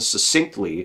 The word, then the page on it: succinctly: American English